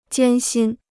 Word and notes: 艰辛: jiān xīn

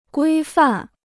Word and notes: 规范: guī fàn

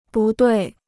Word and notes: 不对: bù duì

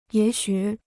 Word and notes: 也许: yě xǔ

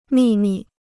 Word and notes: 秘密: mì mì

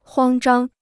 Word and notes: 慌张: huāng zhāng

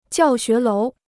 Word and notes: 教学楼: jiào xué lóu

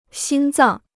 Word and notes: 心脏: xīn zàng

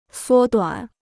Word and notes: 缩短: suō duǎn

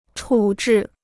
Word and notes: 处置: chǔ zhì

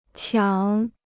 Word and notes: 抢: qiǎng

